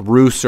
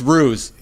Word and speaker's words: Ruse: It's, yeah, I have a hard enough time pronouncing like Roos or Rouse. You know, I have a hard time with any kind of like normal pronunciations for like normal English words. So it's Ruse.